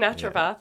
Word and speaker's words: naturopath: Naturopath.